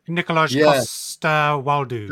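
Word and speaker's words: Nikolaj Coster-Waldau: Nicolaj Coster-Waldau.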